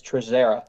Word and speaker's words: Trazzera: My last name is Trezera.